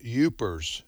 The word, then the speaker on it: Yoopers: And so Upper Peninsula, you call them Upers. – Upers. – Get it? Upper Peninsula? – Uppers, yeah. – Upers. – Oh, I got it. Yeah. – Yeah, Upers. Anyway, Y-O-O-P-E-R-S is how they say it.